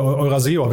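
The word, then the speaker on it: Eurazeo: Eurasio hast du dir rausgesucht, ne? Oder Euraseo werden sie, glaube ich, ausgesprochen. – Genau, ja. Euraseo, glaube ich, genau.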